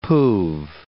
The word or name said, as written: poove